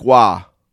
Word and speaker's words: Roy: Patrick Waugh. Pronounced Patrick, or Patrick Roy is how it's written.